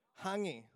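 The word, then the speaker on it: hāngī: In fact, the other day, I think we just went to a Maori village, and we had a hungi. Am I saying it right? Hungi, yep. Hungi. Yeah, the hangi.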